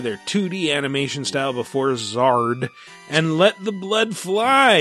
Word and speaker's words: Xrd: That's how you pronounce XRD, Zard.